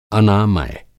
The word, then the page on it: अनामय: Hindi